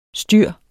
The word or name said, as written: styr